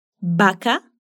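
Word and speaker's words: vaca: Escute esses três exemplos com a letra V: Baca, baca, avião, avião, ventilador, ventilador. Percebeu como o som é o mesmo do B? Então pode falar com segurança essas palavras, pronunciando o V como o B mesmo.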